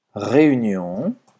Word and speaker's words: réunion: Então presta atenção nessa pronúncia, tá? Réunion, réunion. Esse U faz a boca do U e fala aí.